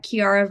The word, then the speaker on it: Chiara: It's at Chiara versus Cancer. My first name is spelled C-H-I-A-R-A, and then the versus is V-S, and then cancer.